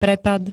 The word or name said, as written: prepad